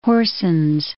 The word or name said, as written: Horsens